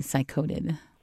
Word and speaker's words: psychodid: Cycoda is spelled P-S-Y-C-H-O-D-I-D, cycoded. – Oh. – As in psycho.